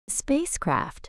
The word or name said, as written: spacecraft